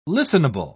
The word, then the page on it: listenable: British English